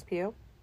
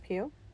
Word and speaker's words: Pugh: Florence Pugh. – Okay, I was confused about how to say her last name. – Pugh. – Pugh. Mm-hmm. It's just Pugh.